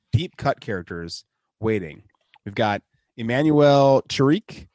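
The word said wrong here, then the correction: Chriqui: Shrieky. – What? – Heard you. Shrieky is how you pronounce her last name.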